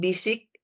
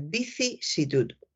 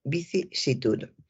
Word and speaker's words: vicisitud: Y hay que tener cuidado porque hay personas que efectivamente se equivocan y dicen vicisitud, dicen vicisitud, que también lo he oído, vicisitud, eh, o dicen visic, visic es una palabra que cuesta. Eh, la unión, estas dos sílabas, sici, vicisitud, eh, son, son difíciles cuando se habla de una manera coloquial y, y deprisa. Así que hay que recordar vicisitud, vicisitud. Continúo con un periodista que estaba hablando de, un político.